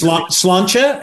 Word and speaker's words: Sláinte: It's Sláinte, and it's the Irish for cheers, and it literally means to your health.